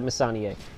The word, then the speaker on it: Messonnier: I'm going to pronounce that Messonnier.